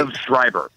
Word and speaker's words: Liev Schreiber: Liv Schroeder and Topes Shemelik Morge. – Wow. – I warn everybody. – Liev Schreiber.